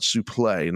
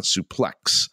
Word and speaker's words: suplex: So that's how, where the X was silent, suplex, not suplex.